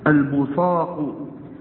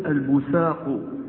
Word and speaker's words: البصاق: يجوز أن تقول البصاق، البصاق بالصاد، البصاق في المسجد خطيئة. ويجوز أن تنطق هذه الكلمة بالسين، فتقول البساق، البساق بالسين.